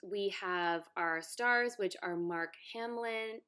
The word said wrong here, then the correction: Hamill: Hamill? How do you say Hamill. – It? Hamill. There we go.